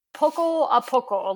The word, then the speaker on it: poco a poco: So I watched your video of you and Will, sorry, your honeymoon climb to, is it Poca a Poca? Am I saying that right? – Poco a poco, little by little.